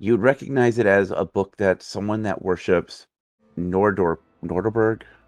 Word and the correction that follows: Norgorber: Is that how it's pronounced? Norgorber. – I've been going with Norgorber.